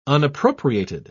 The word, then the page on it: unappropriated: British English